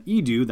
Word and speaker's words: Eadu: Nobody necessarily knew for sure, but it seems like we have official confirmation now that Eadu, that's how you pronounce it, even though it's spelled E A D U, is a new planet appearing in the Star Wars universe.